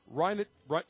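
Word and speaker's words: Reinheitsgebot: In 1516, William IV, the Duke of Bavaria, adopted the Rheingeschbott. I believe I'm pronouncing that correctly. – Rheinhutzgebot.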